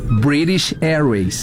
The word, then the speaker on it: British Airways: British Airways.